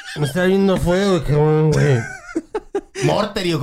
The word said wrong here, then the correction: Mortimer: ¿cómo se dice? Mortimer, Mortimer. Mortimer.